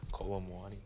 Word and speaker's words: Kolo Muani: Kolo Muani.